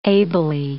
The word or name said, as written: ably